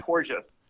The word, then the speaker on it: Porges: Porges. So rhymes are gorgeous, which is...